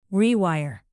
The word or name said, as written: rewire